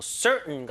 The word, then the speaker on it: certain: 뭐 certain 같은 단어들 괜찮을 것 같은데요? It is certain. 확실하다. Certain. Certain이 아니에요. Certain. 이게 이제 성문 폐쇄음이라 부르는데 T 발음이 완전히 죽어서 목구멍에서 응 이런 소리 나요.